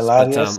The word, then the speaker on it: Latias: My son wants a Latias. I don't know if that's how you say it. – Yeah, Latias. But, I love.